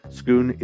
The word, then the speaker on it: Schoon: Schoon is spelled just like school, except with an N instead of an L. That is S-C-H-O-O-N. My name is Ryan Schoon, and with much love from my corner of the cosmos to yours, I bid you adieu.